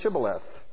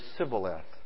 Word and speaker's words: Shibboleth: And if he said no, then they said, 'Okay, you just say Shibboleth.'" And usually if it was an Ephraimite, he would say Sibboleth, for he couldn't pronounce the H. Couldn't say it correctly.